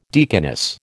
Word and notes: deaconess: American English

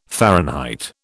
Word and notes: Fahrenheit: British English

